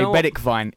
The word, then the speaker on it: Bergwijn: Well, I was going to say Bedikvijn is the actual Dutch pronunciation.